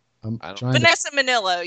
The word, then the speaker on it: Vanessa Minnillo: Vanessa Minnillo? Is that how you say her name? – Vanessa Minnillo. Yeah, they're very cute.